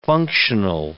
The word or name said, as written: functional